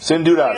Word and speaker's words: Sin Dudas: How do you pronounce that song? – Sin Dudas. – Sin Dudas.